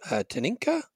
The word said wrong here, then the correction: Tinica: Oh, Tinica, yeah.